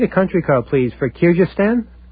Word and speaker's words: Kyrgyzstan: I'm afraid I'm not pronouncing it right. Kyrgyzstan. Yeah, you can do it that way.